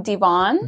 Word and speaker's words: Devonne: You mean Devonne? – Devonne.